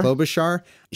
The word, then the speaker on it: Klobuchar: I'm not sure which will win in that battle, but Amy, how do you say her name? Klobuchar? – Klobuchar.